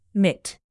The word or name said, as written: mitt